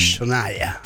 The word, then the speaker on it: Shania: Sí, el nombre es Shania. – Shania. – Pues se escribe China, ¿no? – Sí, se escribe Shania.